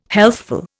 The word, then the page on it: healthful: Indian